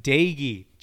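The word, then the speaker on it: Doege: Jarrett Daigie. That's how you pronounce the man's name.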